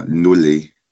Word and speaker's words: Ndole: Spricht man das so richtig aus? – Genau, ja, Ndole.